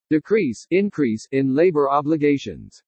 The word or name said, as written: DECREASE INCREASE IN LABOR OBLIGATIONS